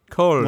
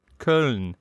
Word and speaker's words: Köln: Köln. – What? – Köln. – Köln. – Köln.